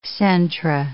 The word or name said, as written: centra